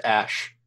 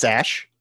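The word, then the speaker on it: Æ: That's pronounced Ash. – Is it really? Is that is that his name? It's Ash.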